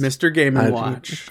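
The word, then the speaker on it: Mr. Game & Watch: Mr. Game ampersand watch. Did you really I believe that's how it's pronounced. – Mr. Game and watch. – Correct me. – Yeah. – He is like, when you first got this character, it's like he seems wildly unpredictable.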